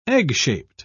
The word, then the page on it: egg-shaped: British English